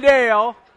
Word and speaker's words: Dale: Jimmy Dale, and you got to say it right, it's Dale, it's two syllables.